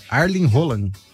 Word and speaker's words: Erling Haaland: Mas se escreve assim, Erling Haaland. Mas a pronúncia do nome é Arlen Haaland.